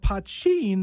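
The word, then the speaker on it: Poitín: And you've got a band called Pochin, though it's spelled P-O-I-T-O-N, which essentially is Irish moonshine.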